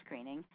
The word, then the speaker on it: Gruning: And then the property went into the hands of a social activist, a fascinating woman by the name of Martha Greening, which is actually spelled Gruning, but it's pronounced Greening.